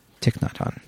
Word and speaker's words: Thich Nhat Hanh: Quote, I always say his name wrong. – Thich Nhat Hanh? – Thich Nhat Hanh, thank you.